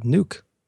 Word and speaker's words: Nuuk: Oh, and I learned today the capital is called Nuuk. That's how it's pronounced. It's not spelled.